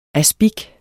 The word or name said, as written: aspic